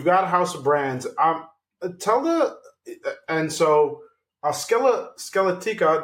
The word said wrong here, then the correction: Skeletina: did I pronounce that correctly? – Skeletina.